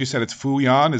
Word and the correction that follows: Fujian: Is that, am I saying that right? The Sturgeons? – Yeah, yeah. Fujian. – Fujian, actually. Okay, the Fujian Sturgeons.